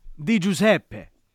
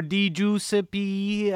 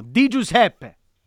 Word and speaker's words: Di Giuseppe: Phil DiGiuseppe. Not Di Giuseppe or Di Giuseppe. No, Di Giuseppe. That's how you say it.